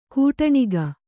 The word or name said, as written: ಕಟ್ಟಣಕ